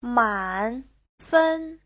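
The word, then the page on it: 慢分: man3fen1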